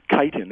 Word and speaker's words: chitin: Okay, so the structure of the cells in mushrooms are held together with something called, I'm not sure I'm pronouncing this right, chitin. It's spelled C-H-I-T-I-N, instead of cellulose, which you would find in plants.